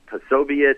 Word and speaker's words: Posobiec: They have fellows like Jack Posobiec, that's how you pronounce his name, like Charlie Kirk.